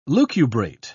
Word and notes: lucubrate: British English

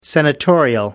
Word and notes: senatorial: British English

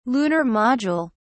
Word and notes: lunar module: British English

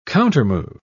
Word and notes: countermove: British English

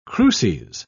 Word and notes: cruces: British English

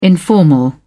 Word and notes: informal: British English

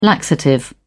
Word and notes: laxative: British English

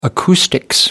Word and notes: acoustics: American English

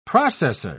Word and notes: processer: British English

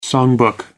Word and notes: songbook: American English

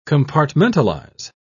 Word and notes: compartmentalise: British English

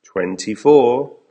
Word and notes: twenty four: American English

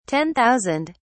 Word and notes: ten thousand: British English

